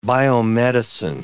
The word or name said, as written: biomedicine